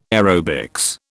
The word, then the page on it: aerobics: British English